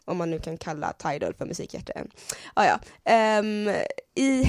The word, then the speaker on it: Tidal: Tidal.